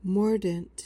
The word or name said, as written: mordant